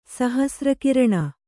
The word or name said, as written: ಸಹಸ್ರಕಿರಣ